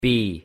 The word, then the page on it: b: English